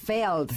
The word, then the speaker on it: Lagerfeld: Estoy viendo que yo lo pronunciaba mal. Siempre le dije Karl Lagerfeld. Y es Feld. Feld.